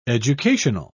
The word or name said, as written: educational